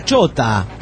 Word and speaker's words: ciota: Bueno, pues, en Polonia tenemos la palabra ciota, que se pronuncia chota, ¿eh? – Ajá.